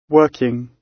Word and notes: working: British English

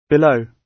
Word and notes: below: British English

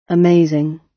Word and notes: amazing: British English

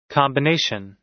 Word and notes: combination: American English

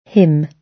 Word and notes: him: British English

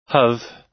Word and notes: have: American English

